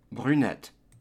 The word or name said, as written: brunette